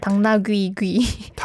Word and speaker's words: 당나귀 귀: And as for the third sentence, actually, it was hard for me to pronounce the consecutive 귀. – There's so many 귀s there. – Yeah, but I think I would just say like 당나, almost like 당나귀 and then 귀. – 당나귀 귀. – Yeah, that's a incorrect pronunciation, but it's just hard.